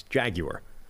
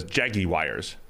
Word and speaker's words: Jaguar: If you're from Europe or you speak English as opposed to American English, it's Jaguar. – Jaguar. – But if you're from... – Neil says Jaggy Wires.